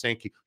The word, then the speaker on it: Sankey: Very interesting timing that Greg Sankey, the commissioner of the SEC, and it's actually pronounced Sankey.